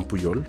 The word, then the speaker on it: Pujol: Eh, existe una historia, hay, existe un español que se llamaba Juan, Juan Puyol, Juan Pujol se escribe,